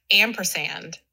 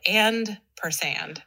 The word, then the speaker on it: ampersand: I think it's pronounced ampersand, but I would always call it and per sand because it's that little and symbol.